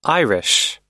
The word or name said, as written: irish